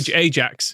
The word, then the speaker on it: Ajax: Ajax is how I was aiming for you to pronounce that, like the cleaning product.